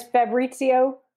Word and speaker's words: Fabrizio: His name is Fabrizio Fab Bellucci.